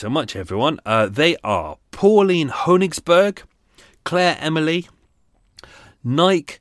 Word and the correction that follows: Nikki: It's actually Nikki, not Nike.